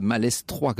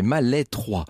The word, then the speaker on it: Malestroit: qui me précise que les Bretons ne prononcent pas le S, en aval le S. Donc c'est pas Malestroit, comme je le dis depuis le début, c'est Malestroit. En l'occurrence,